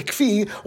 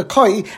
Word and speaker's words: kfi: And this animal is called a kfi, or some pronounce it a kai, and there's even a suffix as to how to pronounce this word.